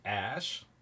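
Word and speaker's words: Æ: Uh, someone else is saying so that AE is uh, pronounced Ash.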